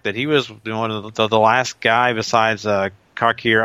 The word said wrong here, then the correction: Kakihara: Kakihara. – Kakihara, thank you.